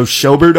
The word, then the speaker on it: Schobert: Joe Schobert, I think, from Cleveland is how you say it.